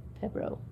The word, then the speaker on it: pero: 67 down, Spanish butt was Perro, P-E-R-O. – Yes. – Did I pronounce that correctly? – Perro, yes. – Okay. – It's good enough.